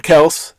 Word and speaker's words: Kelce: So Tyreek Hill, Travis Kelce, or, or Kels, as we learned his name is pronounced this offseason.